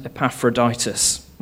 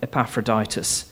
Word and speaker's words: Epaphroditus: Those heroes are not Eric and Ernie, but the heroes from our Bible reading, Timothy and Epaphroditus. And well done, Caroline, for getting his name right.